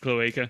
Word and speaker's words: cloaca: It's pronounced cloaca. – Cloaca.